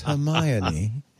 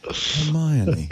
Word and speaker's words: Hermione: And she said, the one question she gets all the time is, how do you pronounce Hermione one? – Wait, what? – It's Hermione. Hermione. Americans apparently don't know how to pronounce Hermione.